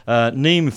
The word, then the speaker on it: Niamh: Neem Fisher-Black. It's that crazy Irish name, N-I-A-M-H, Neem, I think that's how you pronounce it.